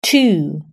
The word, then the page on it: two: British English